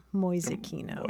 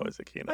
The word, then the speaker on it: Mäusekino: How do you spell that? Mäusekino. – M-A umlaut U-S-E-K-I-N-O. Mäusekino. – Mäusekino.